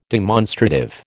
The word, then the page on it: demonstrative: American English